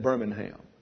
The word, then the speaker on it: Birmingham: You pronounce that Birmingham.